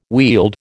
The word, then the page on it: wield: American English